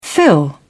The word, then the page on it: fill-in: British English